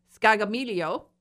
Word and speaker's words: Scaglia: John Scaglia, I have to pronounce it properly. It's an Italian name, Scaglia.